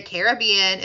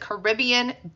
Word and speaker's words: Caribbean: And yes, I do pronounce it Caribbean. If you pronounce it Caribbean, don't come for me, okay?